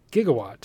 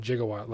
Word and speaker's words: gigawatt: gigawatt. He said gigawatt like Doc in Back to the Future. It's pronounced gigawatt, of course.